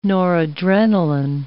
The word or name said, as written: noradrenalin